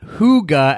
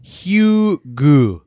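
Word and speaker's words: hygge: Apparently, it's not Huga, as I just said, but it's Hugu.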